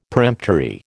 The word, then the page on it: peremptory: American English